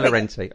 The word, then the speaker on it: Llorente: Yes, do you know how we pronounce it, though? You're going to be really excited by this. – Llorente? – Yes!